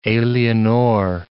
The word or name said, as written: alienor